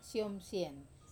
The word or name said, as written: som sien